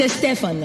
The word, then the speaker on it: Estefan: It's Estefan, though. Second syllable is the accent.